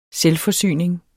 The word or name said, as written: SELVFORSYNING